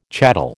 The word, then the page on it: chattel: American English